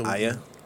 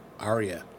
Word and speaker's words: Arya: But now that Arya killed the Aya? – Huh? – Aya? – Arya. – It's pronounced Aya. – No, it's not. Arya. Sure? It's pronounced Arya.